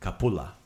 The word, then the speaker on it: kapula: The word for onion in proper Croatian is luk. – Luk. – Right? Luk. But in my family, maybe Dalmatian coast, you pronounce it kapula.